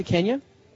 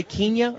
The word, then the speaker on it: Kenya: Do I have to say Kenya or Kenya? – I say Kenya because I was born before independence. Mm-hmm. You say Kenya. Just because.